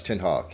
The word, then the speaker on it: Ten Hag: Yeah, he's Dutch. Ten Hag. Yeah, that's how it's pronounced.